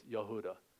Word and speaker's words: Yehuda: In Hebrew, it's the same name, Yehuda. One's the Hebrew version, one's the Greek version, but the proper pronunciation for both is Yehuda.